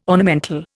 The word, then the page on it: ornamental: Indian